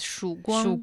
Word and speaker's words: Shuguang: I think it's named Shuguan, if I'm pronouncing it correctly. – In Chinese, it's Shuguang.